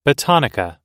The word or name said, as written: botanica